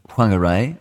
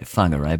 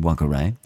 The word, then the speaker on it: Whangarei: When the girl and her family, they've said whānau, moved to Whangarei, they probably pronounce that Whangarei, but it's incorrect. It's an aspirated WH, and just the Maoris want to say fuck, I suppose.